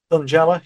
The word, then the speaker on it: Thungela: And am I pronouncing this right? Thungela? – Thungela, yeah, that's how I'd pronounce Thungela.